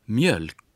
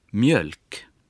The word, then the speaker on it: mjölk: А вот та же буква, но произносится коротко в таких словах, как «молоко». – «Mjölk». «Мёльк».